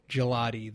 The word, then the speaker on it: Gigliotti: And if you want the odds on this, you could get Joe Gigliotti. The second G is silent.